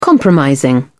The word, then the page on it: compromising: British English